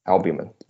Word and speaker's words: albumin: Therapeutically reprogrammed nutrient signaling enhances nanoparticulate, how do you say this? Albumin? – Albumin. – There we go.